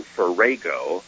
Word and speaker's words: farrago: F A R R A G O. Now, according to the wordsmith, the good news is the wordsmith says that offers us a an alternative pronunciation, Farrago, instead of Farrago.